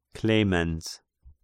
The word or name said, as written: Clemens